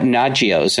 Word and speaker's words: Nagios: What about Nagios? And I don't know if I'm pronouncing that right. – No, you're saying it right.